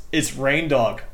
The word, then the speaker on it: Rindog: We're pronouncing this wrong. It's Rindog, like reindeer, but dog.